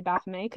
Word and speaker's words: Baphomet: But speaking of gods, there is a statue of Baphomet, or Baphomet, however you want to pronounce it. I'm going to say Baphomet because it's originally a French word.